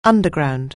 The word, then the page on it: underground: anglais